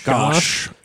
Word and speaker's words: Gash: Previously, the tribe of Gash, or Gaish? How would you say that? – Gosh. – Yeah. Gosh.